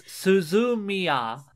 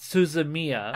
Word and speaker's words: Suzumiya: Because it's Suzumiya, not Suzumiya. – Oh, right. – Yeah, it would all have, like, that flat syllable sound to it.